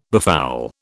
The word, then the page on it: befoul: British English